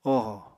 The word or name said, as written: ohò